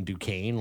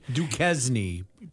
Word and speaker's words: Duquesne: I mean, they beat a no-name in Duquesne last week. – Duquesne. Please pronounce things correctly.